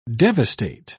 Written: devastate

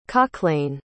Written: cochlain

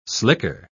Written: slicker

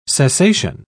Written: cessation

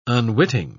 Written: unwitting

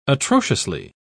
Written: atrociously

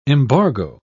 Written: embargo

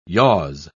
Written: yaws

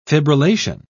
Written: fibrillation

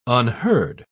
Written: unheard

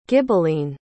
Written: ghibelline

